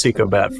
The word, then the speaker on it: Ecobat: I've got with me today Chief Information Officer at EcoBat. Did I say that right? Because I know it's like economy and battery or something put together. – Yeah, it's EcoBat for sure.